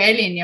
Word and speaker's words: Elin: Ma ei ole veel ära otsustanud, kas ma aksepteerin seda või mitte, et nagu sõpradele ja tuttavatele ma olen ikka Elin ja ma panin selle ee sinna lõppu, et ingliskeele rääkijad oskaksid seda paremini välja hääldada, aga nüüd on minu arust mulle paksaid, et nad ei oska seda ka hääldada.